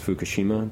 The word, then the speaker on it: Fukushima: Fukushima, did I say that right? – Mm-hmm.